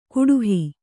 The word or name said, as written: ಕುಡುಹಿ